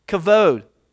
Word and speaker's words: kavod: In the Hebrew, when Moses said, "Show me your glory," in English, it's that word is kavod. I know it's got a B there, but they pronounce it as V. Kavod.